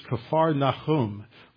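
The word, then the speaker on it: Kfar Nahum (כפר נחום): Now, we pronounce it Capernaum, but in the Hebrew, it's Kfar Nahum, which is the village of Nahum.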